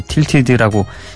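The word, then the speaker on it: Tilted: 타이틀이라고 순간 착각을 해가지고 예, 틸티드라고 예습 때 읽어놓고서도 이런 실수를 저지릅니다.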